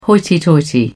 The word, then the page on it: hoity-toity: British English